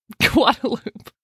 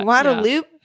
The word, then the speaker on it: Guadalupe: you read it, you were like, that is Guadalupe. This is how Guadalupe is spelled all over the world. – Yeah. – Nope. Come to Austin. It's just Guadalupe.